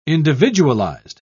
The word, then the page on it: individualised: British English